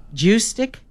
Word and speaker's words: Juszczyk: Juszczyk. That's why they call him Juice. – That's why we just call him Juice. – Yeah, we'll just call him Juice. – Juszczyk.